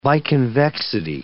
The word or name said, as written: biconvexity